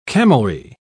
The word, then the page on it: camelry: British English